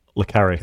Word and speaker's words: Le Carré: Yeah, just briefly, this is a new documentary coming out on Apple TV Plus, and it's about John Le Carré. I don't know if I pronounced his name right, the author of all the spy novels. – Le Carré. – Le Carré.